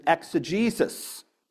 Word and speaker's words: exegesis: is an exegesis, which is a Greek word that means to draw out the original meaning.